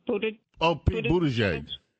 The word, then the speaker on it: Buttigieg: I can't even pronounce his name, the transportation secretary, Buttigieg.